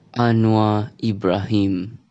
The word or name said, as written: Anwar Ibrahim